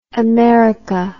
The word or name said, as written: Amerika